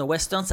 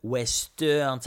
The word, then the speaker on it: Ouesteurne: Western, ça s'écrit comme ça, c'est o u e s t e u r n -E, Western, ça se dit comme ça